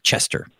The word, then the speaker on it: Colchester: Back in North Carolina, near Asheville, North Carolina, where I used to work and grow up, there's a town called, it's spelled like Colchester, but everybody just says, you know, like Chester.